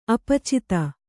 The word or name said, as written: ಅಪಚಿತ